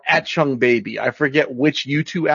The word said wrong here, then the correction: Achtung: How do you say the words? – Achtung. Achtung Baby. It's Achtung German.